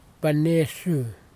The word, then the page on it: penesu: kwamera